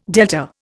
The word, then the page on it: delta: Indian